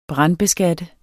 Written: brandbeskatte

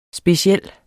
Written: speciel

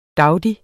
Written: DAUDI

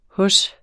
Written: hos

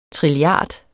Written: trilliard

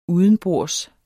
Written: udenbords

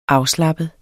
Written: afslappet

